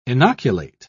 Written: inoculate

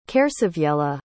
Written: caersewiella

